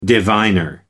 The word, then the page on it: diviner: American English